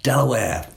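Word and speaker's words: De La Warr: So on the page, it's D-E-L-A-W-A-R-R. But Delaware is how they pronounce it.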